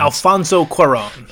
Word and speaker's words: Alfonso Cuarón: Alfonso Cuarón.